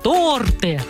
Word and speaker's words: торты: Конечно, правильно говорить «торты». Почему? Да потому что в русском языке есть слова, у которых ударение всегда-всегда остается на одном месте. Ученые, которые изучают язык, специально обращают внимание на такое ударение и называют его «неподвижное». Вот такое ленивое неподвижное ударение в слове «торты» всегда на «о».